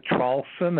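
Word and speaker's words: Traulsen: But, yes, my name is Hugh, H-U-G-H. The last name is Trawlson. That's spelled T like Tom, R-A-U-L-S-E-N like Nancy.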